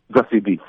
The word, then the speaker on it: Zazie Beetz: If I'm butchering this, tell me, is it Zazie Beetz that plays Courtney? – Zazie Beetz, yep.